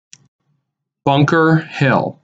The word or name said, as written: Bunker Hill